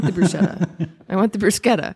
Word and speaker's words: bruschetta: I don't know why people can't wrap their minds around that, like why they can't say the hard sound. No one says zucchini. – Is it spelled the same? – Yes. Oh. C-H-I. Well, C-H, yeah, E. But anyway, that's a huge pet peeve of mine is bruschetta. Please, all of our listeners, do me a favor and say bruschetta.